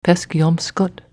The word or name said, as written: Peskeompskut